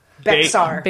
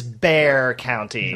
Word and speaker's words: Bexar: So how would you guys pronounce Bexar County? – Bexar. – Behar? – You'd be wrong, because it is Bear County.